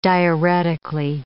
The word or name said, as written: diuretically